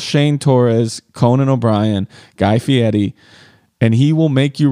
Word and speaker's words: Fieri: I'm so much of a fan that I try to say his name correctly, Fieri.